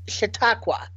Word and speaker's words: Chautauqua: He is from Chautauqua. – Oh yeah, that's right. – That's how you say it.